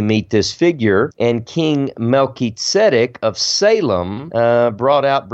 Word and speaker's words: Melchizedek: we pronounced that differently than I just did. We called uh, this guy Melchizedek, okay. Melchizedek, but that's just you know, but but it's Melchizedek.